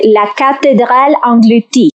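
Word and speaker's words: La Cathédrale engloutie: Está cerquita, se dice La Cacherral Englutí.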